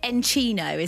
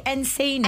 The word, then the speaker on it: Encino: And the album's called Encino, is that right? Is that the right pronunciation? – It's actually Encino.